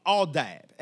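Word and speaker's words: aldab (עבד): The Hebrew word for servant or service in this context is pronounced aldab, and it means to serve.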